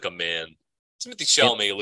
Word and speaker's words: Chalamet: Is that how you say his name? – Yeah, he's Chalamet.